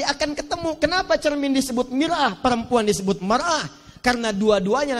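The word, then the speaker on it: mir'ah (مرآة): Ini mar'ah, ini mir'ah. Maknanya bisa beda. Silakan lihat. Ini mar'atun, ini mir'atun. Yang ini perempuan. Yang ini cermin.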